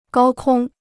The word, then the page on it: 高空: gāo kōng